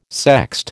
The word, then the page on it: sext: American English